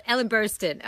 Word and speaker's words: Burstyn: Ellen Bernstein. Oh, Ellen Burstyn.